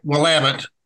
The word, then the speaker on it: Willamette: We call it Willamette, and it rhymes with damn it, so we say Willamette, damn it.